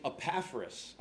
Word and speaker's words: Epaphras: Next line, a man named Epaphras. I'll spell it for you. E-P-A-P-H-R-A-S. E-P-A-P-H-R-A-S.